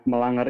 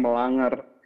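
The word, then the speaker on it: mélange: Nah, melanger itu berasal dari bahasa Perancis yang artinya percampuran. Dan sebenarnya bacanya itu bukan melanger, tapi melange Gitu.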